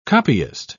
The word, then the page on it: copyist: British English